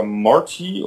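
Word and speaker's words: Morte: Und der erste Charakter, der einem begegnet, ist Mort oder Morty oder wie auch immer der ausgesprochen wird. – Mort. – Mord einfach, genau.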